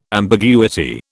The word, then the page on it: ambiguity: British English